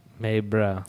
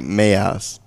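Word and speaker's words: Mais bruh: You would say like Mayas. – No. – Mayas, bruh. – No. – Mayas. M A I S. – No. – Bruh. B R U H. – No. – That's what it says.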